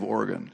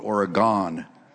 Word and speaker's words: Oregon: This is the first time, actually, can you believe it, that we've ever been in the state of Oregon. So did I say that right? Sometimes I have the accent in the wrong syllable, and I call it Oregon.